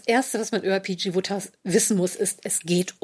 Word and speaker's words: Wodehouse: PJ Wodehouse und das Buch heißt Ride Ho Jeeves und äh, PJ Wodehouse habe ich mir immer mal Woodhouse, Woodhouse wird da ausgesprochen.